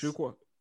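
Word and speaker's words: Achiuwa: It's Precious Achiqua. – Yes. Achiqua. – Did I say it right the first time? – Yes.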